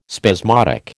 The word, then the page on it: spasmodic: American English